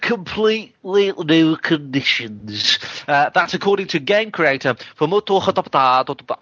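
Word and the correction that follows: Fumito Ueda: I don't know how to say his name. That was very bad. – That's code for the PS4. – No, yeah. That's code for we started again. So, yes. The game creator Fumoto Yuda.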